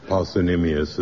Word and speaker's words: parsimonious: Penny pinching is an expression for mean, miserly, tight-fisted, parsimonious, or parsimonious, as some people pronounce it.